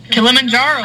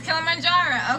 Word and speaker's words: Kilimanjaro: Kilimanjaro. – Oh, Kilimanjaro, okay.